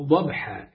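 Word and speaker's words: ضبحًا: طيب أبو العاديات يعني الخيل في الغزو، ضبحة ضبحة، وعندك في النطق بها تنطق ضبحة، الضاد ومفخمة وبعدين الباء مرققة والحاء ضبحة ضبحة.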